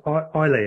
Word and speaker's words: Islay: I think it's said Islay is the correct pronunciation, the word I-S-L-A-Y.